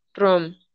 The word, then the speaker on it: Rome: Se llama Mina Rom, pero se escribe Rome. Mina Rome.